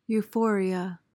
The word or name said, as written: euphoria